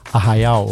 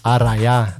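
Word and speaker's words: arraiá: So we can be referred as arraial or arraia, depends where, or arraia, depends which part of Brazil you are, right? – Yeah. – How do you say where you are from? – We say arraia. – Arraia. Do like in the south, like in the countryside, we say arraial because the R has a different.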